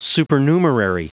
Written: supernumerary

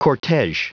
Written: cortege

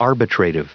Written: arbitrative

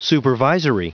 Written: supervisory

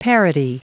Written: parity

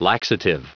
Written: laxative

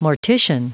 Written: mortician

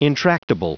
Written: intractable

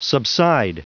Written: subside

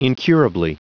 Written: incurably

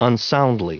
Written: unsoundly

